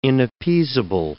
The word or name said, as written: inappeasable